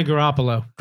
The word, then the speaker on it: Garoppolo: I'm going to butcher his name because Brad's not here to help me with names, but his name is Garoppolo. – Jimmy Garoppolo.